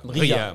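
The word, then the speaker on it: Mria (Мрія): Alors, euh, Emria, Mria, je ne sais pas comment on le prononce. Euh, Mria, Mria, Mria.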